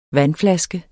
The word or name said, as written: vandflaske